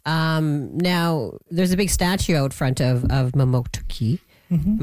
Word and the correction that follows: Mamotokuti: Am I saying that right? Mamotuki? – No, Mamotokuti. – Mamotokuti. Oh, I missed something in there.